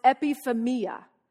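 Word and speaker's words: epithumia: In the Greek, it means it's pronounced epiphemia.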